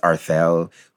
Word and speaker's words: Arthel: Like, some people even make fun of my, I guess they're not making fun of my name, but, like, my name is Arthel, which is, like, if you separate the syllables, like, art and hell together.